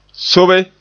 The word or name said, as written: sobe